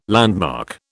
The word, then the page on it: landmark: British English